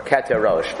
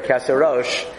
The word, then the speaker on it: Keser Rosh: You know, there's a sefer called Keser Rosh, or Keter Rosh, but I think he probably pronounced it Keser Rosh. The sefer Keser Rosh